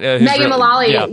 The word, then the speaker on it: Megan Mullally: Megan Mullally, am I saying that right? – Megan Mullally, yes.